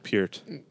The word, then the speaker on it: Peart: Like, there's an interview of Geddy Lee saying it's pronounced Peart.